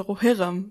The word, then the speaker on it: Rohirrim: Die Betonung auf dem O, ob es das erste ist, das betont wird, oder das zweite? – Zweite. – Also Rohirrim.